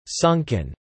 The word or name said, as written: sunken